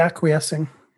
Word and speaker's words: acquiescing: Guys, she's trying to say acquiescing. – Acquiescing.